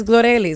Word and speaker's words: Glorelys: Yeah, I mean, everyone calls me Glory. It's Glorelys. It's just hard to say.